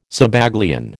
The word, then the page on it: Zabaglione: American English